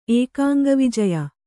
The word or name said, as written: ಏಕಾಂಗವಿಜಯ